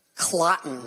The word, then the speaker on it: Cloten: One of the great names in Shakespeare, Clotten. – Rhymes with rotten.